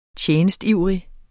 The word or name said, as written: TJENSTIVRIGT